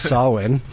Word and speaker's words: Samhain: In other news, Kevin Smith does not know that it is actually pronounced Samhain. – Technically. – Technically, that's how it's pronounced.